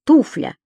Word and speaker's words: туфля: Единственное число — туфля. И ударение правильное поставили на 1-м слоге.